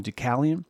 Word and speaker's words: Deucalion: It's spelled Ducalon, Deusalon, but but a lot of people call him Deucalion.